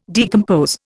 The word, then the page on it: decompose: Indian